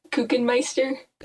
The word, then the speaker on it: Kuchenmeister: It's pronounced Kuchenmeister. – Okay. – Like Cookie Monster.